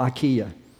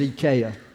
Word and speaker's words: IKEA: Here they y'all when this mispronounce it, it's called IKEA over here, but over there it was IKEA.